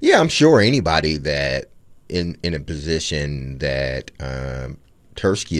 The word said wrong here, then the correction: Tulsky: is in, is that how you pronounce it? – Eric Tulsky.